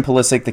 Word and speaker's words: Pulisic: Christian Pulisic, the kid from Hershey, if you didn't hear, he plays for Borussia Dortmund in the Bundesliga in Germany.